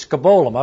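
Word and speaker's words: skabolum (σκύβαλον): The word in the Greek is skabolum. I've got that written down there, and that's spelled correctly. Skabolum. That's the Greek word.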